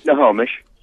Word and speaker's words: Snohomish: It's Snohomish.